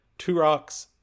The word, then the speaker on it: Turok: but there's an official source that says Turok.